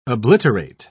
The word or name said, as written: obliterate